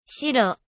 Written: shiro

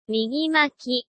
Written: migimaki

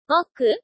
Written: moku

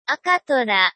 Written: akatora

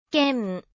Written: ken

